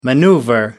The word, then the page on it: maneuver: American English